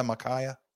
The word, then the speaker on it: Micaiah: And how do I pronounce that name there, Charlie? Micaiah? Like Isaiah, Micaiah? – Yeah, I guess so, yeah.